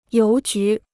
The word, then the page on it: 邮局: yóu jú